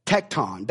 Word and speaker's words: tekton: In the Greek, it's pronounced or it's said tekton. That's the word.